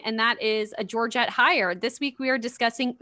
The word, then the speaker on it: Heyer: I've been pronouncing it Georgette Heyer because that is how Richard Armitage, Dickie Arms, the hot dwarf himself, Thorin Oakenshield, that's how he pronounces it at the beginning of his Audible.